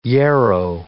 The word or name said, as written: yarrow